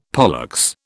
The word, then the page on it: pollex: British English